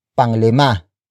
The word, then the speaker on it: panglima: If the number begins with a consonant, like lima, the hyphen is then dropped and it becomes panglima.